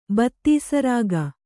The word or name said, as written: ಬತ್ತೀಸರಾಗ